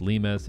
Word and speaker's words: Limes: That's spelled Limes, but it's pronounced Limes.